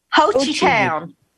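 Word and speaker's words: Hochatown: And what's in the, how you pronounce that town again? – Hochitown. – Hochitown.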